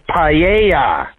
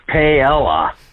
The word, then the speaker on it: paella: You were kind of saying it as it reads. You were pronouncing the L's. Cut two, please, Taylor. – Hey, Howie, it's paella. Not paella.